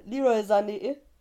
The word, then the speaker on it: Sané: Nein, Sadio Mané hat Leroy Sané, habe ich das richtig gesagt? Ja, ne? Ja.